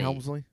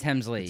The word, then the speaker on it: Hemsley: It's Is that Hemsley. Helmsley? It's Hemsley. It's Hemsley. yeah, yeah.